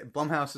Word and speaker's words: Blumhouse: It was directed by Lucy Jordan, and it was at least in part made by Blumhouse, Blumhouse, that's how you say it.